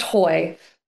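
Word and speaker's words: Høy: There's a Scandinavian slash in the O, and hopefully that gives people a little bit of a pause. But it is pronounced hoy. And it means hill in Danish.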